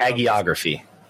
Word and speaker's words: hagiography: But you're really tempting fate by, you know, putting out this hagiography, I can never pronounce that word, hiography of Vince before we even know this terrible thing that is potentially coming down the road. People were talking hagiography.